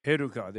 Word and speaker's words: Heruka: Heruka, they're called Heruka. Like how I always think of Hercules myself. Oh, I say here, yes, cognate with Hercules.